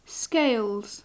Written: scales

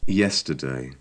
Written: yesterday

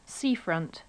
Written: seafront

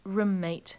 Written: roommate

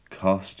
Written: cost

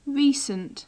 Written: recent

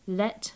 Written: let

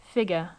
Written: figure

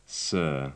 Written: sir

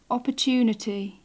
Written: opportunity

